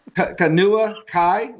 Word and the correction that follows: Kahanu: linebacker, is in the current class. Would I have that right? Anybody know about? I'm not sure I'm pronouncing. – I'm sorry? – I'm not sure if I'm pronouncing his name right. K-A-H-A-N-U-K-I-A. A linebacker. – Are you talking about Kahanu? – Yes. There you go.